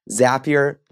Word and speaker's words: Zapier: So sorry, everyone who had to sit through a Zapier ad, but they taught me it's like happier. Zapier, like happier. Zapier. – So there's no Z? – Zapier. But you have to pronounce it Zapier like you're saying happier, but Zapier.